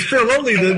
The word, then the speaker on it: Filoli: and we went to a place called Filio. I'm sorry, am I saying it right? Filoli. – Oh, Filoli, yeah, it's Filoli.